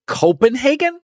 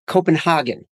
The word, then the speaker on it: Copenhagen: Copenhagen? – It's pronounced Copenhagen.